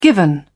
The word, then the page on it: given: British English